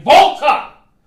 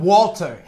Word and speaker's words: WALTER: There's no fucking way the NXT UK Championship is going to be represented by anybody else other than Volta. – Yes, in Tampa, Florida. – I'm sick of people calling him Walter. His name is Volta. Volta. Pronounce his name right.